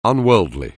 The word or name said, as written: unworldly